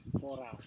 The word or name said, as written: forau